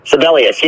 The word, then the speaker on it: Sibelius: Sibelius, is that how you pronounce the favorite, the 5? – Sibelius, yeah.